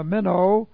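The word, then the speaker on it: meno (μένω): Mone, M-O-N, long E. Mone, and mone comes from the verb meno, which looks like this in the Greek, M-E-N, long O, meno.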